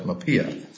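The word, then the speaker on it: MHPAEA: It's a good question as to how that acronym is pronounced, but the folks at the Department of Labor pronounce it MAPEA.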